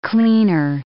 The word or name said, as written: cleaner